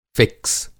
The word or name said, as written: फ़िक्स